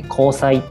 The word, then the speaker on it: 光彩: 光に彩るって書いて光彩って読むんですけど、